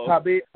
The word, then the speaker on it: Khabib: How do you say they got nigger? Not nigger. – Khabib. Say his name, Khabib Nurmagomedov. – Nurmagomedov.